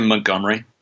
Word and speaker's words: Montgomery: Either way, whether it's Ty Montgomery, whether it's Nile Davis, is it Montgomery or Montgomery? How do you say his last name? – I'd just say Montgomery. You know, just say it kind of quick, just kind of, you know, breeze right by it.